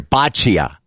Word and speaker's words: boccia: The Lions Pavilion will have a chance to try, how do you say this? Bocce. Isn't that like food poisoning? No, that's botulism. This is different. – Illness at a spot. – They have the pronunciation here. Boccia.